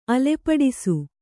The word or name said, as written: ಅಲೆಪಡಿಸು